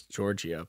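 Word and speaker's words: Giorgia: Are we going to say Georgia? Is that how we're going to do it? Is that how we're going to say her name? – It's Georgia, but I don't know.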